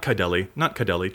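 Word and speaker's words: Kydele: I was fairly sure it was going to be Kaideli and Thrasios. – Oh, no, not Kaideli, just Kaidel. It Sorry. Rhymes with Adele.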